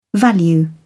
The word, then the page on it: value: British English